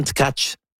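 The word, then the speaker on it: Tkáč: Tack, mais c'est pas la façon de la prononcer. Il y a Yuraï Benko qui nous mentionne aujourd'hui qu'on prononce Anton. Il dit prononcez bien vite en tchèque Anton Tkach.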